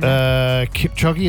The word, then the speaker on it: Kipchoge: Kipchoge, is that how I say his name? – Yeah.